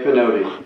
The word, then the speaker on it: Pinotti: It is Ray Pinotti.